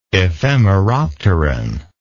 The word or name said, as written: ephemeropteran